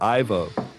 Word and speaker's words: Ivo: This is Minister Evo Oppelsten. – Evil Oppelsten? – On Wednesday, Dutch Security and Justice Minister, or it's IVO. How would you say that? Like a Dutch guy. Is that Evo or Ivo? – TiVo?